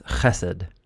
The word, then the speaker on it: chesed: The Hebrew word is chesed. This is one, you got to clear your throat. – Chesed. – Okay, yeah. Is it chesed or chesed? – Chesed. – The emphasis is on the first syllable.